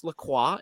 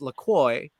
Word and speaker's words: La Croix: It's actually pronounced La Croix in like French, mm-hmm. but they decided it's pronounced La Croix, like enjoy.